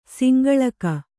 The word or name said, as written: ಸಂಗಳಿಕೆ